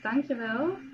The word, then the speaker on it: dankjewel: Thank you is dankjewel.